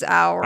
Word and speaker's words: Oura: I always try to pronounce it to give away that it's not Oura, but we say Oura. – It is Oura. Really. – Oh, that's weird. Yeah. It's O-U-R-A.